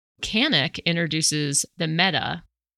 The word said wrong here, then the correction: Mete: I think that's how you pronounce it. – Mete. – Okay. Mete. Canik just announced two new pistols based on the TP9 series.